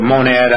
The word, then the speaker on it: Monad: In the introduction, we called it Monad. I hope that's the correct pronunciation.